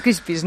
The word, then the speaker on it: CRISPR: Sí, es el CRISPR. – Que siempre lo pronunciamos mal. – No es un CRISPR, lo has dicho perfectamente.